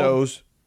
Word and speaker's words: Tso's: You know, he's still getting that General Chow's. Is that what it's called? – So's. – Is that how you pronounce it? So's? – So's, yeah.